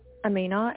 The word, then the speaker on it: Amina: Amina, A-M-I-N-A. – And your surname? – I'll spell it for you, C-E-H.